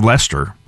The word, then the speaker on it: Leicester: Well, we're going to finish out tonight's show with another interesting group, the Earls of Leicester, which is also a play on word for Earl Scruggs and Leicester Flat, of course, but spelled like Leicester is in Leicester Square in London.